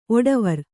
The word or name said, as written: ಒಡವಾರ